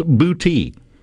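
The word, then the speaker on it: Boutte: It's B-O-U-T-T-E. It's actually pronounced booty. To the point to where when my wife moved to Mississippi, she thought Bude was pronounced beauty. Or booty. Honest mistake to make because, that's what she was used to, but it's pronounced booty.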